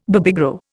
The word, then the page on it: Babygro: Indian